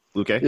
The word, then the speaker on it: Luque: Yeah, I think Vincente Luque, is how you say his name? Luque? – Yeah.